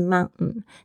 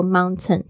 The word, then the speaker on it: mountain: 哦，这个字呢，因为它在 n 的后面，就是这个 t 的声音呢，会有一个潜规则，就是它常常会听不到。所以呢，你会常会听到是 mountain。但是如果你觉得不太会、不太习惯这样的发音的话，请要求自己听懂，还是说 mountain，绝对会。别人会听得懂。